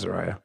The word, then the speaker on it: Zaria: Is that how you say that? I don't even know if how you say that. Huh? It's spelled Z-A-R-I-A. I don't know. But that's Zaria. That's where she's from. – Probably Zaria. – Zaria? – Yeah.